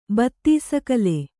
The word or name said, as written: ಬತ್ತೀಸಕಲೆ